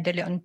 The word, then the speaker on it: De Leon: Ang tumatawag po sa akin ay si Miss Sonia De Leon po, ang Chief Admin po ng Cash Division and Department of Education.